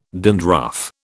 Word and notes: dandruff: British English

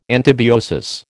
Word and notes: antibiosis: American English

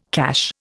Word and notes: cache: Indian

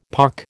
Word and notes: pock: American English

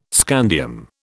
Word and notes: scandium: British English

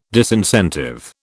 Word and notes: disincentive: British English

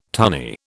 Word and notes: tunny: British English

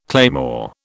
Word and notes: claymore: British English

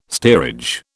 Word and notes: steerage: British English

